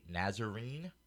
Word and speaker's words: Nazarene: He shall be called a Nazarene." Am I saying that right? – Mm-hmm. – Yes.